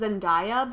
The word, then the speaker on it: Zendaya: I always thought it was Zendaya, but every time I see her on TV, she's...